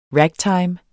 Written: ragtime

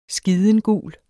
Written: skidengul

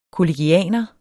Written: kollegianer

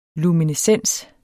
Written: luminescens

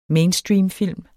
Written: mainstreamfilm

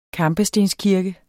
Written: KAMPESTENSKIRKE

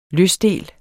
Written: løsdel

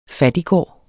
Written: FATTIGES

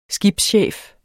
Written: skibschef

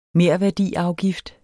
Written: merværdiafgift

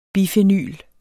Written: bifenyl

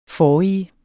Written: forrige

